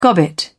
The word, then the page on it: gobbet: British English